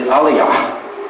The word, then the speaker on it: Elijah: Now, one thing about Elijah, first of all, actually, the right way to pronounce his name is Aliyah. You notice the end of the name is Yah, Yahweh.